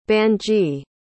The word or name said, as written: banji